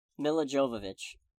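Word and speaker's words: Milla Jovovich: Mila Jovovich.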